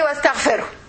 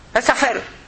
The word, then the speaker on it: wastaghfiru: In the Quran, you must pronounce every letter. So how to pronounce a word like this and to pronounce every letter in it, I will divide it. Wastaghfiru. Wastaghfiru. This is how we read it like this, you know.